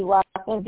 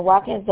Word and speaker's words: Watkins: Bessie Watkins, B-E-S-S-I-E. Last name Watkins, W-A-T-K-I-N-S.